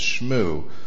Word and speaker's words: Shmoo: We usually use the French pronunciation of that as well, which is Shmoo.